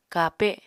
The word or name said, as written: kape